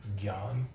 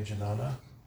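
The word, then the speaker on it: jnana: Anytime you see that J-N, that's pronounced like a G-Y. Yeah. Like Gyan. You could say Janana. Or Gyana. It's, either way, if you drop the A at the end, you can do that. Gyan.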